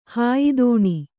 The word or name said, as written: ಹಾಯಿದೋಣಿ